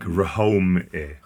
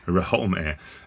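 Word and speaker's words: Rhoame: So it's R-H-O-A-M-E, like Rahome. Rahome.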